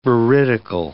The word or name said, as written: veridical